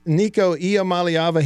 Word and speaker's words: Iamaleava: Right, here, I'm going to give it a chance according to his pronunciation. His name, and here I go. I'm going to do my best. Nico Ia Maliava.